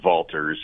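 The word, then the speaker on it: Walters: And there's a great restaurant called Valters, spelled with a W, pronounced Valters, in downtown Salt Lake City.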